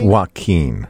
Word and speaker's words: Joaquin: 765 says, Joe King Phoenix is not a correct pronunciation in any way. It's Jacqueline. Didn't I say Jacqueline? – Yeah, Jacqueline? It's Joaquin.